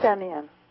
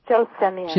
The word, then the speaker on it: Gelsemium: And then you have gelesium, which I usually think of. – Gelsenium. – I'm saying it wrong. Okay, say it again. – Gelsenium. – Gelsenium, I'm saying it wrong. G-E-L-S-E-M-I-U-M.